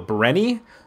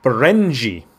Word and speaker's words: Berenyi: Her last name, it's Berenyi. Now you look at it, you go, Brenny? No, it's a hard J because it's Hungarian. – Okay. – Berenji. Mickey Berenji.